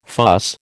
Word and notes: fuss: American English

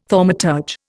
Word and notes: thaumaturge: Indian

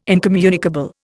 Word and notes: incommunicable: Indian

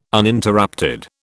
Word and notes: Uninterrupted: British English

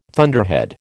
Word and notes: thunderhead: American English

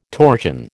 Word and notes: torchon: American English

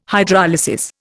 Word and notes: Hydrolysis: Indian

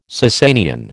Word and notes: Sassanian: American English

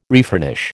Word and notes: refurnish: American English

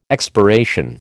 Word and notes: expiration: American English

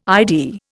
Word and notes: ID: Indian

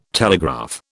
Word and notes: telegraph: British English

